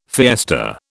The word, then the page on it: fiesta: British English